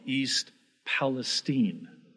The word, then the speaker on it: Palestine: Why do I pronounce it like that and not Palestine? Because, first of all, that's how they pronounce it. You know, just last week, very interesting, precious family from New Palestine, Indiana. He said, we're from a very small town, New Palestine. He said, Palestine. I said, why are you saying Palestine? That's the accurate, actual transliteration of Philistine. Palestine. Philistia. More so than Palestine. He said, that's how we pronounce it. That's how the locals pronounce it.